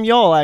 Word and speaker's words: Youghal: Yeah, she's from Youghal actually. Yokel. So, yeah, y- Yogel. Yeah, Yo Chill as a spelling. – Yo Chill. As a, yeah, literally that's how it's spelled. Yo in, uh, Chill. In uh, in Gaelic, it's just like Yo Chill.